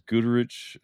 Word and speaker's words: Goderich: And also to mention, he is a sponsor of the Great Lakes knife show, and that will be Goderich. We keep saying it wrong. It's Goderich, Ontario. And that's on August 19th, if you can get yourself there.